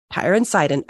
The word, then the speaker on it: Tyre and Sidon: Tyre and Sidon.